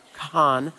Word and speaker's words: Kahan: There's a singer named Noah Kahan, very silent H, I've been told many times, Kahan.